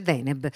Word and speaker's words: Deneb: Grazie, ho detto giusto? – Grazie a voi, giustissimo. – Ecco, perché ho sempre il dubbio che l'accento sia sbagliato, pensa che scema. Invece Deneb, la stella più luminosa della costellazione del Cigno.